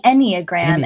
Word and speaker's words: Enneagram: It's the Enneagram. And it's Ennea is 9 in Greek and gram like diagram.